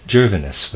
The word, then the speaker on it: Gervinus: Gervinus was his name. I'm going to spell it that way. G-E-R-V, as in victory, I-N-U-S, Gervinus.